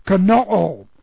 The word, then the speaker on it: kenoo: Perfect passive indicative of kenoo. K-E-N-O, long O.